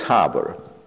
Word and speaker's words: Haber: is Fritz Haber, H-A-B-E-R, Haber, or if you pronounce it in English, it would be Haber,